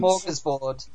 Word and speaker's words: smorgasbord: Smorgasbord. – Oh, Smorgasbord.